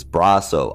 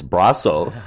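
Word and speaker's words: Brosseau: And I have been corrected by him twice for saying his name wrong. It is Brasso. I thought it was Brasso. I mean, I've pronounced it a bunch of different ways, and finally he yelled at me. So it's Brasso.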